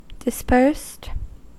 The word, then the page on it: dispersed: American English